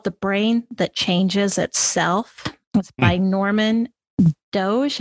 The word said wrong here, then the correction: Doidge: and I always get his name wrong. It's Doidge. And it really digs into the aspects of our brain and how our brains are really plastic.